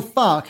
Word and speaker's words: Farke: So, Daniel Farke has the Dortmund Revolution up and running. Am I pronouncing his name correctly? – I think that's right.